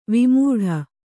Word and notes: ವಿಮೂಢ: vimūḍha

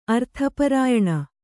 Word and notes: ಅರ್ಥಪರಾಯಣ: kannada

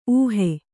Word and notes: ಊಹು: kannada

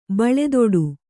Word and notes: ಬಳೆದೊಡು: baḷedoḍu

